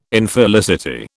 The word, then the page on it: Infelicity: British English